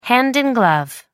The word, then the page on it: hand in glove: American English